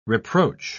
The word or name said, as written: reproach